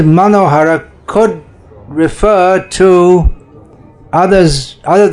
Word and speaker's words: Manohara: Okay, name 461 in the Sri Vaishnava commentary on Sri Vishnu Sahasranama is Manohara, Bengali pronunciation, Monohara, which is easy to understand.